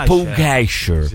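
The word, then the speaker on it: Pogačar: Comunque, visto che ci tiene la pronuncia esatta dei nomi, Maurizio, si pronuncia Pogacar, no Pogacar. – Pogacar. – Si pronuncia Maurizio Pugacher.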